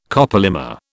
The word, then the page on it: copolymer: British English